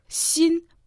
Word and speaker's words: 薪: «Син» произносится в первом тоне, значит «хворост дрова» чан во втором доне.